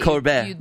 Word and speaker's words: Colbert: He was on Colbert. Is that how you say his name? – Are you starting your French Duolingo? – No, not yet, but I'm trying. – That would be right. – Colbert.